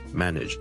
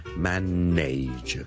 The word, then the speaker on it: manage: 萧叔今日想同大家讲 manage 呢个字嘅发音，manage M A N A G E manage。由于呢个字后面 N A G E 咧，nage 咧系串做 N A。好多人就会将它读成 manage 的，但事实上我这个字是读 manage nudge nudge nudge，你当是好像 nidge 那样读。